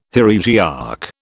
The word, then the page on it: heresiarch: British English